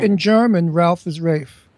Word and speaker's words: Ralph: How come Ralph Fiennes is pronounced Ralph Fiennes when his name is Ralph? – I have no idea, but it's Ralph. I know. It's Rafe. Well, because it's like salmon. It's S-A-L-M-O-N.